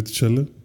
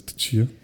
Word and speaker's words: Tchia: No, o sea, se llama, pues, así como se... Así como se escribe Tachala. Tichia. Pero es Tichia. Se me hizo interesante, güey.